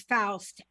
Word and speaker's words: Faust: His name is Faust, F-A-U-S-T, and it's Faust Ruggiero.